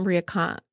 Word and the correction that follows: Marie Kondo: Oh, Marie Kondo.